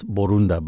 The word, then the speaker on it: Borunda: Almost. It's Borunda, but that's great.